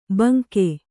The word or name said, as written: ಭುಂಗಾ